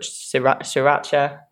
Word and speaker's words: sriracha: Sometimes I put sriracha.